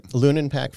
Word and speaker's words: Lunin-Pack: Am I saying that right? – Lunin-Pack Financial Group, yep.